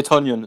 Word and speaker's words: Tonyan: der One-on-One -on -one mit Robert Tonyan steht. Oder Graham, einem von beiden. – Nee, Tonyan ist richtig.